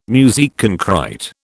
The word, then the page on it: musique concrÊte: British English